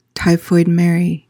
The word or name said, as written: typhoid mary